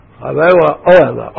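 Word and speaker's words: האהלה: Oela is one of those words in Hebrew which is accented on the pre, ante, penultimate, ante, penultimate syllable. Those of you who want to be Baalei Kriah, you have to know that.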